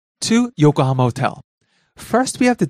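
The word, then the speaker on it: Yokohama (横浜): Yokohama. – Yokohama, the city. – Yokohama. Yokohama. – Starts low, rises on the second syllable ko, stays high.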